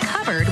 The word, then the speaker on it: cupboard: When we come back, why it's pronounced covered when it's spelled cupboard?